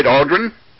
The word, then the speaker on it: Aldrin: Aldrin? – That's correct, yes. – It's almost like the astronaut there, or just the same.